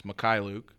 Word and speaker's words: Mykhailiuk: And then Svi Mykhailiuk, I finally pronounced how to say his last name. It's Mykhailiuk. He was named to the second team All-Summer League, which was cool to see another Laker getting some love out in Vegas.